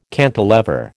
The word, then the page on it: cantilever: American English